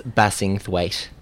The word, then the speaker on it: Bassingthwaite: How do you say it properly? – It's Bassingthwaite. – Bassingthwaite. – Thwaite. – Thwaite. – Thwait. Thwait. Ended in a t.